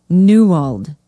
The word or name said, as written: newald